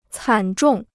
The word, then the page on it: 惨重: cǎn zhòng